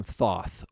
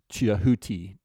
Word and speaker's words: Thoth: In Egyptian mythology, they called him Thoth, or that could be pronounced Tehuti, Tehaoti, if you pronounce all of the letters in there. So he's often talked of as Thoth, because that's how we'd pronounce T-H-O-T-H in modern English.